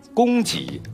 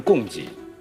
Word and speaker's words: 供给: 先提一点小小的字音方面的，亚鹏的这个供给啊，不能念成供给，是一的这个潜力，不是潜力，啊。